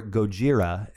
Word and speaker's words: Gojira: So Godzilla, or Gojira, as it would be pronounced in Japanese, is a fictional monster, or kaiju, originating from a series of Japanese films.